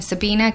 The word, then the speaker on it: Sabina: It's just my name, Sabina Gadecki. That complicated. – You have to spell it. – Gadecki, and like Sabrina, but no R.